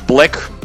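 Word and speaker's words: 6lack: И у нас здесь, как открытие года, должен быть выделен американский исполнитель, чье имя пишется как Six Leg, а произносится как Black, просто Black.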